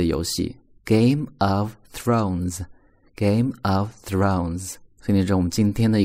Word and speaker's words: Thrones: 首先，其次呢是有一个双元音，o，throne 加 s，z 读 z，thrones，thrones，Game of Thrones，Game of Thrones。